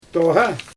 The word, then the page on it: tooɣa: Xyzyl